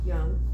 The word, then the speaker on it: Jung: Jung, thank you.